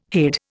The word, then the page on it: hade: Indian